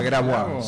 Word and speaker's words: Grabois: ¿A Grabois? – Sí, señor. Los bien pronunciamos Grabois, ¿no? – Oye, francés. Sí, algo tengo que haber aprendido.